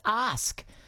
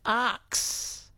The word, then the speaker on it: ask: Michael from Bundaberg says, ask. Seems to trip up a large portion of the population who pronounce it arx.